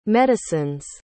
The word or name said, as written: medicine's